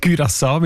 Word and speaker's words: Curaçao: Curaçao mit Ü in dem Fall.